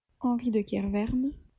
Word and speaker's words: Henri de Kerverne: Eve helpfully also included an audio clip with the, in fact, correct pronunciation of his name, which sounds like this. – Henri de Kerverne.